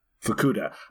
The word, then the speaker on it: Fukuda: I think Fukuda, how do you pronounce it again? Fukuda.